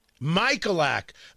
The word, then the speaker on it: Michaelak: Michaelak.